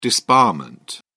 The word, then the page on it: disbarment: British English